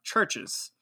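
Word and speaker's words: CHVRCHES: Churches. And that's spelled, instead of a U, it's C-H-V-R-C-H-E-S.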